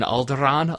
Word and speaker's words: Alderaan: and he trills the R in Alderaan a little bit.